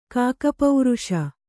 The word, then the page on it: ಕಾಕಪೌರುಷ: Kannada